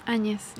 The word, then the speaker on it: Añez: But very quickly, and I'll try and pronounce it, Jeanine Añez? – Añez. – Yeah. Añez.